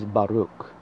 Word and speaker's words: Baruch: The second word I'd like you to learn is Baruch. B-A-R-U-C-H. It's pronounced Ba-Ruch. Notice the Ch at the end. It is a guttural sound.